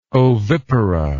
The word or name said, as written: ovipara